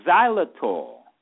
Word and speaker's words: xylitol: Another one is xylitol. Let me spell that one for you. as in Malcolm, XYLITOL.